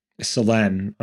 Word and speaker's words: Solène: It's Solène, I recently learned. – Solène. Okay. We're doing it better, hopefully. – The French corrected him.